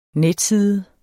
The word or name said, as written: netside